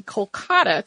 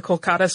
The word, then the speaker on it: Kolkata: At that point, the Indian government renamed the city Kolkata to more closely reflect the Bengali pronunciation.